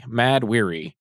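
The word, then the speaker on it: Mahd Wy'ry: For those who want to look it up, it's spelled M-A-H-D-W-Y apostrophe R-Y. Mad Weary.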